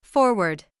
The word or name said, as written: forward